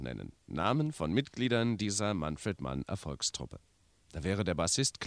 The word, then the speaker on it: Manfred Mann: Aus einer persönlichen Begegnung mit ihm weiß ich übrigens und möchte es gern weitersagen, Manfred Mann spricht sich Manfred Mann aus und nicht Manfred Mann und nicht Manfred Mann und nicht Manfred Mann, sondern Manfred Mann. Also richtig treu deutsch.